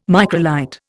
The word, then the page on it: microlight: Indian